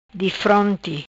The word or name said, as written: Difronti